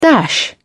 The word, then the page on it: dash: British English